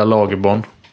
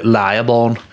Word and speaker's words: Lagerborn: Jag fick sms från Sevo Speaker som sa att hon vill att man uttalar det Lejeborn.